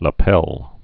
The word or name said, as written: lapel